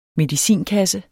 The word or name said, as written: MEDICINKASSE